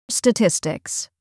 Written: statistics

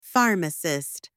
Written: pharmacist

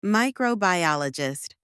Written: microbiologist

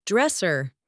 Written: dresser

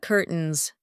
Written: curtains

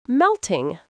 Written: melting

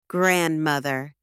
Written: grandmother

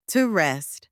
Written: to rest

